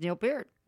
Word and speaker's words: Peart: Just Neil Peart.